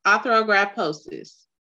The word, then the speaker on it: arthrogryposis: The AMC is called arthrogryposis.